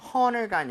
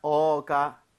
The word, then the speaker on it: honor: H가 묵음이기 때문에 네. – honor가 아니라 어가 맞습니다.